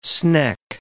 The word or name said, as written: sneck